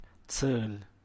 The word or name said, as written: zhu’b